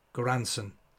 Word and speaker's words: Göransson: And have I butchered? I put in work as Ludwig. Tell me that line. – Göransson. Ludwig Göransson.